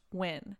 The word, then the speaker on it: Nguyen: My name is Jimmy Nguyen, pronounced Nguyen, and I'm a longtime fan/listener.